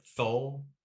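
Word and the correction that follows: Thule: T, I never, oh yeah, the tuli? – There we go.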